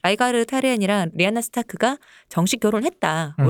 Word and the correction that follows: 타르가리엔: 원래 그때 라이가르 타르엔 발음이 안 돼. 라이가르 타르가리엔이 그 마르텔 가문의 네.